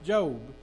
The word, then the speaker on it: Job: Uh, it's spelled Job, but it's pronounced Job.